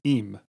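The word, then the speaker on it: im (עם): In Hebrew, the word nation is spelled the same as the word with, pronounced am and im.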